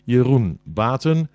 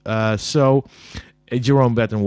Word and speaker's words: Jeroen Baten: My name is, I'm Dutch, so my name pronounced in Dutch would be Jeroen Baten. I know that sounds awful if you're not Dutch. Jerome Batten was just as well.